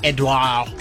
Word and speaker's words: Edouard: Edouard, the D is silent. Edouard. – Okay. – Edouard. You have to sound like someone's choking you with fingers.